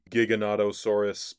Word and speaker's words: Giganotosaurus: It's actually Giganotosaurus, but the jig is up. I end up pronouncing it Giganotosaurus, I believe, throughout the rest of this episode.